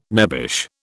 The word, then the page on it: nebbish: British English